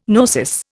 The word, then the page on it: gnosis: Indian